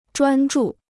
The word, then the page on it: 专注: zhuān zhù